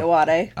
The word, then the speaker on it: Ayoade: Ayoade.